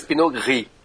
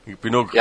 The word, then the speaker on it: Pinot Gris: É Pinot Grigio, é isso? – Pinot Grigio. – Grigio, Grigio. – É Grigio. Ou em francês, Pinot Gris. – Pinot Gris.